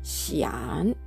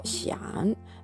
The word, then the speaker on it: 想: えーこの漢字が当たっているんですが、発音記号は xiang と書いて、シアンと読みます。第三声。これも低く下がる、落ちる音です。をシアン。